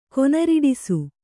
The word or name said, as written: ಕೊನರಿಡಿಸು